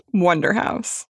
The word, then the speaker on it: Wunderhaus: This is the story of Wonderhouse, spelled the German way. Like Wunderhaus? – uh, this is America, so it's pronounced Wonderhouse.